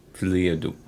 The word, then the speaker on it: folie à deux: So, actually, how do you say the first one? Folie à deux? – Folie. – Folie à deux.